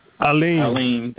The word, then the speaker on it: Alim: And I want to say, hey, is it Dr. Amil? I want to make sure I say your name correctly. – Alim. – A-L-I-M. Alim. I said mm-hmm.